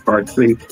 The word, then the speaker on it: Parsee: How you say your last name? Parsee? Parsee? – Parsee.